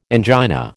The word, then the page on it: angina: American English